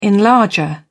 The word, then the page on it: enlarger: British English